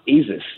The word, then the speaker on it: Asus: Asus. All right. – Asus? – As a comparison, Asus. – Asus. – It's actually pronounced Asus, you know. I know they say, you know, okay, Asus. But it's Asus.